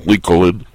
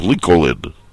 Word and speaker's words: Lincoln: Just know here that they don't accept the silent L in Lincoln.